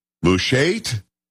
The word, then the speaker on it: Mushait: M-U-S-H-A-I-T, Mushait? Can we say it? We're going to go with that. Paul, are we good with that? You're not top of that, are we? – Say it the way you need to say it. – Mushait? – Yes. – Yes, that's good. That's good to you get Aaron if you say it like that.